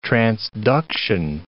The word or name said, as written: transduction